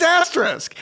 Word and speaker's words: asterisk: it's asterisk. It's A S T E R I S K. Asterisk.